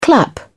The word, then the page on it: clap: British English